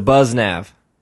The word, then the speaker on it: BuzzNav: The Buzz Nav.